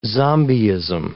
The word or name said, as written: zombiism